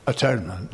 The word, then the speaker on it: atonement: And that completely changed my perception and view of atonement. Mm-hmm. I just wanted to check that at-one-ment is kosher, so to speak. It's correct. I haven't changed the meaning. – Yeah, it's either way you pronounce it.